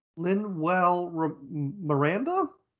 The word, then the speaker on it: Lin-Manuel Miranda: Lin-Manuel Miranda? – There you go.